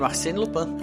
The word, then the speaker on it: Arsène Lupin: Espero que eu tenha feito a. – pronúncia correta é isso aí mesmo, Arsène Lupin.